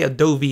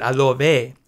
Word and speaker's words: Adobe: They were like, you don't say Adobe, you say it Adobe.